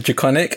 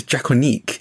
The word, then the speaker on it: Drakoniques: so oh, I pronounced it Draconic, and then I got a very dirty look from a French person in the same stream as me. I was like, You pronounce it Draconic? It's Draconique. I was like,